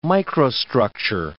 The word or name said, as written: microstructure